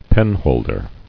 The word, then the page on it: Penholder: pen·hold·er